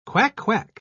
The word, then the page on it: quack-quack: British English